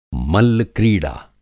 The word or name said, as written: मल्ल-क्रीड़ा